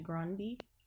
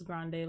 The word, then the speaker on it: Grande: And I think she pronounces it, her family pronounces it Grande or something versus Grande, like we all say.